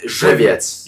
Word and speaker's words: Żywiec: Beer, Żywiec. It's actually not like. We will both agree it's not the best. – How do you beer. – Say it? – Żywiec.